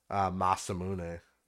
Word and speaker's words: Masamune: They had the, I remember in particular, they had Sephiroth's Masamune.